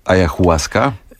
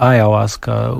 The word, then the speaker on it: Ayahuasca: Czy dobrze wymawiam słowo Ayahuasca? – Tak, tak. Chociaż można powiedzieć, że istnieje też dopuszczalna wersja Ayahuasca, upraszczana, ale nie ma tutaj chyba jakiegoś puryzmu językowego.